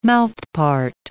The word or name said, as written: mouthpart